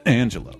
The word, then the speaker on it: D'Angelo: Is that how you say his name? – Mm-hmm. – D'Angelo.